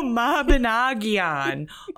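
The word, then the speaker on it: Mabinogion: Mobbinoggin. – Quit mobbing on my noggin. – No, Mobbinoggion. Oh my God, it's even more than that.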